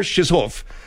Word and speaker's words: Chizhov: It looks to me like C-H-I-Z-H-O-V. Chizhov. That's what it is, bud, so we don't get your filth infecting this segment of the program. – All right. Vladimir Chizhov Ambassador to the European Union said the Russian presidential administration is ready to make the call.